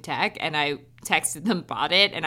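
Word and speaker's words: Bot It: So basically this Bought It, which is, I need to clarify, is spelled BOT-IT. – I just realized that it's a homophone for BOUGHT.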